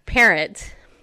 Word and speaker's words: Perrotte: So my maiden name is Parrot, and it's spelled P-E-R-R-O-T-T-E. So it looks like Parrot, okay?